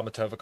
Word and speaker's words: Matovcik: Yeah, you can find me, Kyle Matovic, on Twitter.